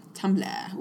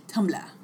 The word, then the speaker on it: Tumblr: Tumblr. Ooh, I said it wrong. You're right. Tumblr.